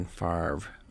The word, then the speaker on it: Favre: But the R comes before the V in Favre.